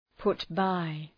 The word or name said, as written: put by